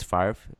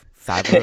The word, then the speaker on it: Favre: because we were talking about why his name is pronounced Favre instead of Favre, like Lucien Favre is.